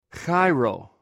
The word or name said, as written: cairw